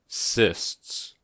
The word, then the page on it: cysts: American English